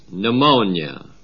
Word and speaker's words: pneumonia: We do not pronounce the P, it is pneumonia.